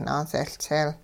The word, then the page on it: naaseLtseel: hupa